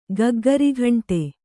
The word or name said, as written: ಗಗ್ಘರಗಂಟೆ